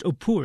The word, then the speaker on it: Apu: When we first meet Apu, pronounced Opur in the film, he's a wide-eyed little boy from a poor rural family who's surrounded by vivid relatives.